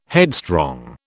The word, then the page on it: headstrong: British English